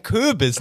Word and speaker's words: Köbis: Ein Köbis, nicht Kürbis.